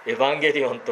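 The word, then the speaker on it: エ ウア ン ゲ リオ ン (εὐαγγέλιον): 福音と訳されている言葉は、ギリシャ語ですけれども、皆、知ってる方もたくさんいらっしゃる、発音的にはエウアンゲリオンと言いますが、エヴァンゲリオンと言われたりも、していますけれども、エウとアンゲリオンで切れます。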